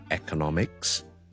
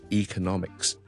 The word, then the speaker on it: economics: 听一次，第一个系 electronics，第二个系 electronics 亦都得，有好多字都系类似咁样，譬如经济学啦，我哋 economics， or we can say economics 亦都得，两个都系广为接受发音嚟。